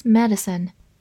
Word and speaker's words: medicine: son，medicine，或者中间字母 d 后面的 i 可以不发音，medicine 也是可以的。它是一个名词，表示药或者医学。比如说 Chinese medicine 就是中医中药，Chinese medicine，或者跟它对应的西医西药叫做 Western medicine，Western medicine。